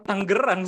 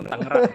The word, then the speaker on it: Tangerang: Tangerang itu cara bacanya Tangerang apa Tanggerang sih sebenarnya? Soalnya lo tadi bilangnya Tangerang gitu kan, sedangkan gue taunya Tanggerang gitu. Yang bener yang mana? sebagai lokal, iya, orang sebagai lokal representatif. Orang orang Tangerang sendiri bacanya Tangerang.